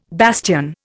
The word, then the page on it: bastion: Indian